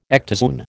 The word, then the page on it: ectozoon: American English